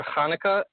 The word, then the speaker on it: Hanukkah: Hope you guys are enjoying your Hanukkah.